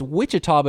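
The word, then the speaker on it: Ouachita: Is that O-U-A-C-H? – I believe it's pronounced Wichita, but it's O-U-A-C-H-I-T-A, Baptist.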